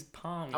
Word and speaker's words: Parmi: It is Parmi.